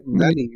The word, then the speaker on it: Manny: Manny. You know, like the Manny.